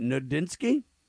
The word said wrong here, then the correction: Nodlinski: Nudlinski? Yeah, he's there in Nebraska. – Nudlinski, Kurt? – N-O-D-L-I-N-S-K-I. So he's got to be a Polish guy, Nudlinski.